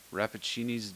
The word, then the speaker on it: Rappaccini: Partly inspired by the titular character in Nathaniel Hawthorne's short story, Rappaccini's Daughter?